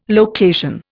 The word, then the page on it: location: Indian